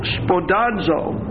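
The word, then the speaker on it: spodazo (σπουδάζω): And the Greek is spodazo, S-P-O-U-D-A-Z-O, S-P-O-U-D-A-Z-O. That second O is a long O, making every effort.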